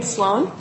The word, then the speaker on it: Sloan: My name is Adrienne Sloan, S-L-O-A-N.